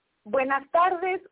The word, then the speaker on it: buenas tardes: Buenos tardes. How's that? Is that right? Nobody's there. How do I say this? Buenos, what do I say? Buenos? – Buenas tardes or buenos dias.